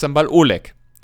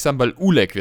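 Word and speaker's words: Sambal Oelek: Nein, und dann, hab ich, dann haben wir tatsächlich, ich habe gesagt Sambal Oleg. – Und dann habe ich gegoogelt und es das heißt wohl wirklich Sambal Ulek wird es ausgesprochen.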